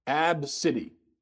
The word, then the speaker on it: Abcde: Her daughter's name is spelled A-B-C-D-E. The letters A-B-C-D-E. That's what she named her daughter. It's pronounced Ab-City.